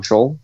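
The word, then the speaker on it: Soule: Charles Scholl, I'm saying his name wrong, Scholl, right? – Yeah. Mm-hmm.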